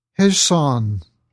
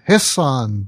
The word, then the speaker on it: Hejsan: He says, Hejsan, Dr. Dave, or is it Hejsan, Dr. Dave? It's spelled H-E-J-S-A-N.